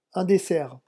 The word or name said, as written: un dessert